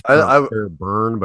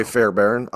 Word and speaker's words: Fairbairn: I don't know if it's pronounced. It's Bruce Fairbairn. It looks like Fairbairn, but I think it's Fairbairn. But I'm going to say Fairbairn. I'm going to agree with you on that.